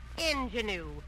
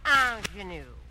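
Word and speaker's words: ingénue: Mr. Haley, the word is not ingenue. It's a French word, and it's pronounced ingenue.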